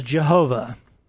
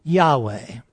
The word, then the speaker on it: Yahweh: God's personal name is Jehovah. That's how most people say it. But actually it's spelled YHWH in Hebrew. There are no vowels, just consonants. And the closest pronunciation we can come to it is Yahweh.